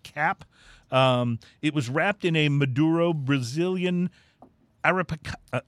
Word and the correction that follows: Arapiraca: Let me try to pronounce this right. It's the Arapiraca. Leaf, Arapiraca, leaf. I'm not super familiar with that strain of tobacco right.